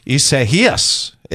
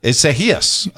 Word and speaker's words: Isaias: Have we settled on the pronunciation? Isaias. Isaias, otherwise known as the Spanish pronunciation of Isaiah.